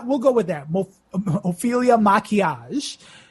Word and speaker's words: Maquillage: So it's Ophelia, Ophelia, Maquillage. The L's are silent. So that's M-A-Q-U-I-L-L-A-G-E.